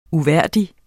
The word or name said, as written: uværdig